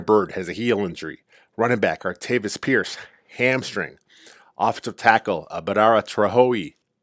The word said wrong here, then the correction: Badara Traore: I'm butchering that name. I know I am. Badara Traore, knee injury.